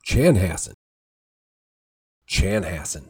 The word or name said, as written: Chanhassen